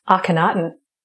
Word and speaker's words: Akhenaten: That's our good friend, Akhenaten. – Akhenaten.